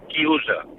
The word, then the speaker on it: chiusa: Y un momento, y quiero decirle a Fernando que en italiano se dice chiusa. Coro a boca chiusa.